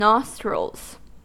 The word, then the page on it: nostrils: American English